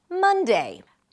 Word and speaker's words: Monday: The next is Monday. Monday starts with an M sound, then another cup vowel sound, ma, ma, and an N. Mun, mun, mun. Just like in the word month.